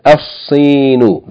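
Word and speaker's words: Al-Sinu (الصين): Yani, instead of saying Al-Sinu, like we said, El-Yabanu, here the first letter of the word, Sin, is a sun letter. So we're going to, yani, merge the lam into the sun letter. We're going to double the sun letter and pronounce it Al-Sinu.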